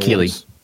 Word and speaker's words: Keighley: Other than that, we got the big event, which is Opening Night Live, which was hosted by Jeff Knightley. He's the guy who does the VGA Awards, the video game Jeff Keighley.